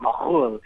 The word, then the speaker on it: McKhool: It's actually it would have been pronounced McKhool in Kafr-Mishki, Lebanon, this tiny little Christian village right in the middle of Lebanon where my ancestors are from.